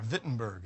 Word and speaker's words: Wittenberg: German town of Wittenberg, or Wittenberg as they pronounce it.